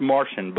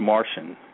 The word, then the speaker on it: Marcion: Martian. It's pronounced Martian, but it's spelled, you're right, M-A-R-C-I-O-N. – Right. – But it's pronounced Martian. I know that sounds like a Martian from outer space, but that's just how it's pronounced.